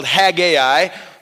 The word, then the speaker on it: Haggai: and you will find Haggai. Haggai oftentimes is called Haggai or Haggai. Either one is fine. I'm going with a two-syllable version for our series.